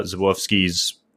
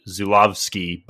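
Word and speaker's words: Żuławski: I think it is like Zulawski, but I think with a Polish accent, that's where you get Zawofsky, you know.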